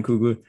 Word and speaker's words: Coogler: Ryan Coogler. Is that how you pronounce his last name? – Yeah. – Ryan Coogler.